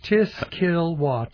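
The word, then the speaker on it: Tiskilwa: That's Tiskillwah, T-I-S-K-I-L-W-A, a Potawatomi Indian name for Gem of the Valley.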